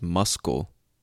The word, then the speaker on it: muscle: Or muscle, M-U-S-C-L-E, which, if you think about it, is muscle, right? What the fuck is the letter C doing in the word muscle? It doesn't make sense. Doesn't make sense. Or marriage. Marriage. M-A-R-R-I-A-G-E.